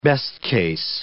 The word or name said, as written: best-case